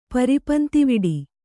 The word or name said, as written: ಪರಿಪಂತಿವಿಡಿ